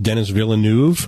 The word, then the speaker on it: Denis Villeneuve: The director, I think his name is pronounced Denis Villeneuve. – Yes, we were looking at this earlier. I'll agree with that.